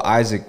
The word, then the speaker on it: Isaac: Like it's kinda like people spell Isaac weird a lot.